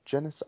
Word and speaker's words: Genisys: I think it's called Genisys. I think that's how you say it. – Uh, Genesis misspelled because yeah.